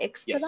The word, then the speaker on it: Expedock: Did I say that correctly? Expedoc? – Yes, Expedoc.